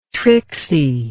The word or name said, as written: trixie